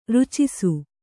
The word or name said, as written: ರಚ್ಚಿಸು